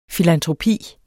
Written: filantropi

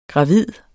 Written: gravid

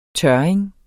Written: tørring

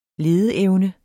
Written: LEDEEVNE

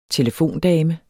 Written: telefondame

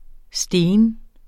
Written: stene

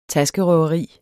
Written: taskerøveri

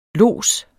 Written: lods

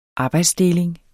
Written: arbejdsdeling

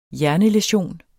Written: hjernelæsion

